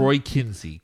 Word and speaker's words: Kinsey: Roy Kinsey. K-I-N-S-E-Y.